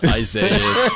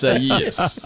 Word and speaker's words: Isaias: Like they're like, it's not Iglesias, it's not Isaias, it's Isaias.